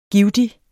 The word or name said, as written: givtig